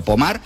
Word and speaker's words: Pommard: O sea, estamos hablando que son imágenes que si las buscáis, sí, Pommard, se escribe Pommard, como si fuera en castellano, pero Pommard, pues allí en Francia, hablamos de unas imágenes que podían pasar.